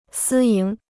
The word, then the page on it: 私营: sī yíng